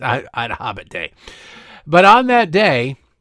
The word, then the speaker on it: IDAHOBIT: Today is Ida Hobbit Day. Ida Hobbit Day. And that's not somebody saying, you know, I'm the Hobbit. You think you're the Hobbit. No, I'm the Hobbit. It's spelled that way. It's spelled I-D-A-H-O-B-I-T.